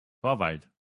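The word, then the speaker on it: Torvalds: I mean, how do you say that? – Torvald. – Oh, yeah, okay. We just say Torvald.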